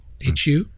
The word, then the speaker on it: Hu: And this is about a student, a Chinese design student, whose name is Elvin Hu, H-U, right? Am I saying that right? – Hu? Yep, sure.